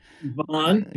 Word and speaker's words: Vaughan: Am I saying Vaughan right? – Vaughan. Yeah. Okay. The telemarketers would say Vaughan.